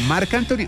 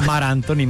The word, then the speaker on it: Marc Anthony: ¿Marc Anthony o Mar Anthony? – No, no, lo digo? Mar Anthony, Mar Anthony.